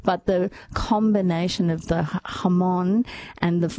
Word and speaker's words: jamón: And it's not jamon. It's spelled jamon with a J, jamon. But it's jamon.